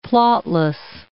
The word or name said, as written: plotless